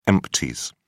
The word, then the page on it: empties: British English